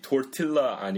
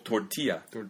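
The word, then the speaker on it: tortilla: 발음으로 하면 케사딜라 아니고 똑같이 토르틸라 아니고 토르티야. 케사디아. – 케사디아. – 네. L 두 개 나오면 항상 Y로 합니다.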